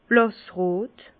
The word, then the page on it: blàssrot: Bas Rhin; Herrlisheim